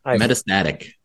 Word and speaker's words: metastatic: Metastatic. – Thank you. I was putting the syllable on the wrong syllable.